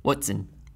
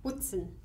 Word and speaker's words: Hoatzin: Hoatzin. – Hoatzin. – Just like the name, except without a T. – Hoatzin. – Yes. – Hoatzin. – The H is silent.